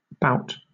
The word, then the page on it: bout: British English